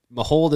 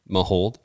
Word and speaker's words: Mahowald: So Mahold is the pronunciation, but like when you say it fast, you really only say the two syllables, Mahold, but there really is three. – M-A-H-O-W-A-L-D.